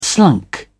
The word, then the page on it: slunk: British English